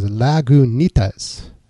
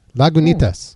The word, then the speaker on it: Lagunitas: They even say how to pronounce it. It says Lagunitas. Lagunitas.